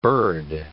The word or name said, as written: burred